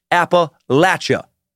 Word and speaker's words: Appalachia: Number four, Appalachia. That is the primary and most correct pronunciation. Appalachia, mostly for northern Appalachians and outsiders like me.